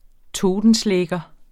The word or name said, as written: totenschlæger